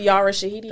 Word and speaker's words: Yara Shahidi: Yara Shahidi.